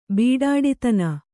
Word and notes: ಬೊಡ್ಡಿತನ: Kannada

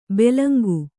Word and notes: ಬೆಲಂಕು: belanku

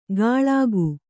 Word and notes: ಕಳ್ಗು: kaḷgu

